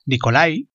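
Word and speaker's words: Nikolai: Hoy aquí en Crónicas Lunares hablaremos de Las Almas Muertas, una de las máximas obras de Nicolás Gogol, Nicolai, como se pronunciaría normalmente,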